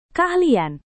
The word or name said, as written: keahlian